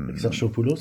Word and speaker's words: Exarchopoulos: Exarchopoulos.